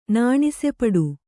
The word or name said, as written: ನಾಣಿಸೆಪಡು